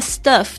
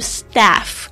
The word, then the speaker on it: stuff: 特别注意一下 stuff 的发音，那个 U 发的声音不是 staff， staff 就变成就是员工的意思了。